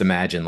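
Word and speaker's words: Emedgene: It's spelled EMED gene, but pronounced Imagine, like the John Lennon song.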